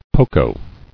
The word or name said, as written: Poco